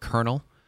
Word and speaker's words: colonel: We just talked about off the air word colonel because that's annoying. Why is there an L in there? Colonel. – Uh-huh. – C-O-L-O-N-E-L. That's just silly. – That's how you spell it? – Yeah.